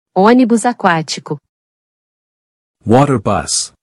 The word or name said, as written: Onibus aquatico